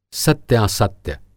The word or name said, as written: सत्यासत्य